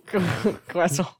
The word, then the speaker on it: croissant: So when we say it wrong, croissant. Horribly wrong, yeah, we pronounce the R like an R instead of a W.